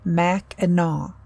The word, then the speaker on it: Mackinac: which is pronounced Mackinac, despite its spelling.